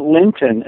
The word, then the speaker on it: Linton: I pronounce it Linton, and only because I grew up in a city named Clinton. So I'm actually Spencer Linton from Clinton, and my dad harped on us all the time to make sure the P was enunciated correctly.